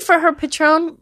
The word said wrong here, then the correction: Patreon: or am I saying it right? Patreon. Patron. I'm thinking of tequila.